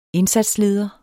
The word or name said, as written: indsatsleder